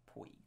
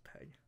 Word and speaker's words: Pegg: Poig. – It's Pegg.